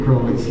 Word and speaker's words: Crowley: Anyway, it's pronounced, this is the way, because people during Crowley's lifetime were mispronouncing, so he made a little limerick thing to show how to pronounce his name properly. My name is Aleister Crowley, I am master of magic unholy. And so Crowley and unholy rhyme.